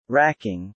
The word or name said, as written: wracking